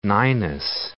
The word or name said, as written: Ninus